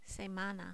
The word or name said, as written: semana